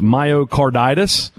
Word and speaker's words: myocarditis: Myocarditis, maybe? You did a great job. You got it right.